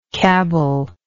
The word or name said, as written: cabble